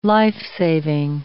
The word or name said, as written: lifesaving